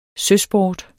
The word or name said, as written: søsport